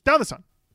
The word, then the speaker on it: Downthesun: So if you want to be truly honest with it, it's Downthesun. Very quickly.